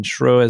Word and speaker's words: Schreuwer: I'll spell it because my surname is very difficult to spell. It's Dr. Dr. Greg, G-R-E-G. And Schruer is my surname, S-C-H-R-E-U-W-E-R. So it's all one word, Dr. Greg Schruer.